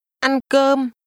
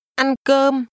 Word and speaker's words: Ăn cơm: Ăn cơm. Cơm. Cơm. Ăn cơm. – Did you pronounce the two mid-level tones at the same pitch?